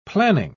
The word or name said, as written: planning